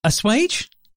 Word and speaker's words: assuage: What's the etymology of us of Auswage? – Auswage. – How do they pronounce it? – Assuage.